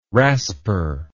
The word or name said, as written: rasper